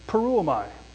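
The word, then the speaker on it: Peruomai (πορεύομαι): It's peruomai. Peruomai. Peruomai. It's four syllables.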